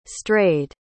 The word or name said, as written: strayed